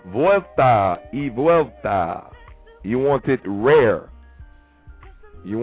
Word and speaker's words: vuelta y vuelta: V-U-E-L-T-A. Y, which is pronounced E. You know what I'm saying? Vuelta y vuelta. V-U-E-L-T-A. E-V-U-E-L-T-A.